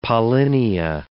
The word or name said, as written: pollinia